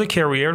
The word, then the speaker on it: career: اولا اینکه تلفظ career رو فکر میکنم که بعضی از بچه ها مشکل دارن. فرق career و career، این استرسش روی R هستش.